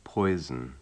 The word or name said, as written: poison